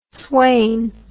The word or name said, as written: sweyn